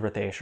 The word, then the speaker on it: Ritesh: Okay, so the correct pronunciation is Ritesh, right? – Mm. Uh, but in America, people uh, reference me as Ritesh.